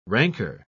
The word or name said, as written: rancor